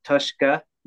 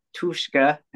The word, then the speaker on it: Tushka: It's supposed to be Tushka, not Tushka.